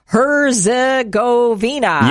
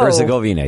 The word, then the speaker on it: Herzegovina: And I have to make sure I pronounce this correctly. Herzegovina. – Yes, good job. – Whoa. – Herzegovina, yeah.